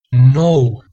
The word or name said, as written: nou